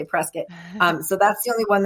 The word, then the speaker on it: Prescott: Oh, and that I wanted to tell you, if you're walking around doing the shops and you want to, it's spelled Prescott, but the locals, it's Prescott, like business. – Whoops. – So it's Prescott.